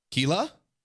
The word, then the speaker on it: Keila: Because my name is spelled K-E-I-L-A. – Keila? – See, exactly. No, it's or not Kayla. Keila. It's Kayla.